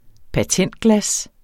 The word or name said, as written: patentglas